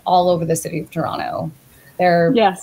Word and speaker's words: Toronto: And I too am based in Toronto. The T is silent, and I love raccoons.